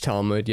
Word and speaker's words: Talmud: The Talmud even lays. Am I pronouncing that right? – I think it's Talmud.